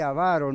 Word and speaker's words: avaro: que ningún fornicario o inmundo o avaro, fíjense que dije avaro, no avaro, Correcta pronunciación es avaro.